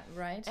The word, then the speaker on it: Wright: Ah, ja, Wright.